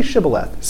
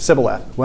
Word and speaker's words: Shibboleth: Say Shibboleth. Sibboleth.